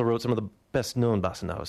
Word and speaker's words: Bossa Nova: 1963, that was Baden Powell with Improvisio and Bossa Nova, which is how they pronounce Bossa Nova, Bossa Nova.